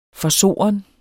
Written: forsoren

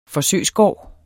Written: forsøgsgård

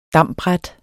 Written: dambræt

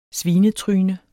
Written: svinetryne

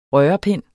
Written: rørepind

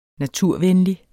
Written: naturvenlig

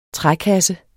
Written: trækasse

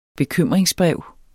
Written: bekymringsbrev

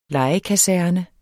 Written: lejekaserne

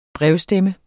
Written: brevstemme

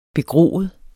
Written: begroet

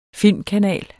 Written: filmkanal

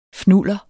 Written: fnuller

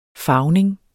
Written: farvning

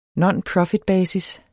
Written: nonprofitbasis

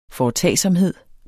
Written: foretagsomhed

